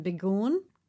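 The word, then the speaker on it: Begoun: It was founded by Paula, how do you say her last name? Begoon? – Yeah.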